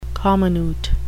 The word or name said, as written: comminute